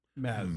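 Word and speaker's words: Mads: He wrote, it's pronounced M-A-Z. Maz.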